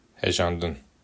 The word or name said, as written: hejandin